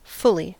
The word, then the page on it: fully: [ˈfʊl.li]; American English